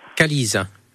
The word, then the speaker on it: Qualyse: Calise. – Ça s'écrit ? – Q-U-A-L-Y-S-E.